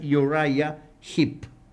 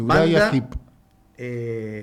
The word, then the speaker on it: Uriah Heep: Pero se deja siempre atrás a Uriah Heep, o Uriah Heep, como que quieran llamarle. La pronunciación correcta es Uriah Heep. – Exactamente, Uriah Heep.